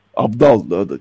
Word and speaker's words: Abdal: E, grup Aptal. Abdal ben yanlış e, telaffuzumu e, becerememiş olabilirim. Grup Abdal ama ne demek istediğimi anlıyorsunuz.